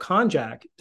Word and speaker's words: konjac: And about konjac. Did I pronounce that correctly? – Yeah, you did. That's the way I pronounce it.